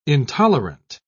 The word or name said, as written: intolerant